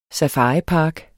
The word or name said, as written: SAFARIPARKEN